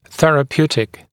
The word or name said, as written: therapeutical